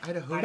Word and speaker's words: Idahoan: There's no I. – What? – Yeah. Idahoan. That was from Spellcheck. – Idahoan. – Who's the ho? Idahoan. – Okay, Idaho. So Spellcheck is right, so your pronunciation is wrong.